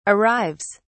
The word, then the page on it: arrives: British English